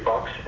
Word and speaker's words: Fox: Patrick Henry Fox, FOX.